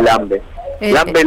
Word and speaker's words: Lambe Lambe: para poder terminar cada caja Lambe Lambe. Lambe Lambe es la pronunciación correcta acá en la Argentina.